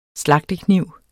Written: slagtekniv

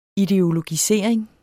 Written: ideologisering

